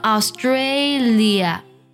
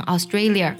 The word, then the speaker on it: Australia: 那么类似呢，还有这几个国家容易读错，特别容易给后面加儿化音，像 Australia 不要读成 Australia， Malaysia 不要读成 Malaysia， Korea 不要读成 Korea。